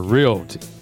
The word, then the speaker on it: Realty: I used to say it wrong. Stacy would get mad at me. She said, "You're not saying that right. It's Realty."